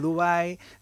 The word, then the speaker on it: Dubái: El caso es este concretamente, porque ella vacunaba, viene de Dubái, también eso sí lo he dicho bien, venía de Dubái,